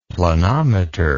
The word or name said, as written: planometer